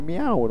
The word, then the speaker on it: Miao: Miao Ya jadi Miao dong bacanya Ya kucing Miao.